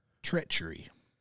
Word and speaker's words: treachery: Treachery. – Slow it down to the three syllables.